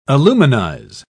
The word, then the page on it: aluminize: British English